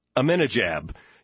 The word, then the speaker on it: Ahmadinejad: How do you exactly do you pronounce that? Ahmadinejad.